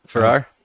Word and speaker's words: Farrar: Yeah, Farrar.